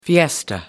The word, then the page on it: fiesta: American English